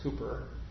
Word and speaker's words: Cowper: It's spelled Cowper, but I think it's pronounced Cooper.